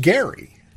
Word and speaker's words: Gerry: started pronouncing it, they looked at, oh, it's gerrymandering, but it was actually pronounced Gerry.